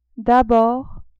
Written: d'abord

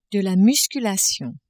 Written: musculation

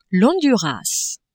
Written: Honduras

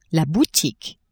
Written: boutique

